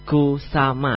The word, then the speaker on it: Kusama: Kali ini aku bakal sebuah informasi satu lagi nih dari Yayoi Kusuma. Ya, ini seorang seniman asal Jepang. Oh, I'm sorry, I miss the pronunciation. It has to be Yayoi Kusama, not Kusuma.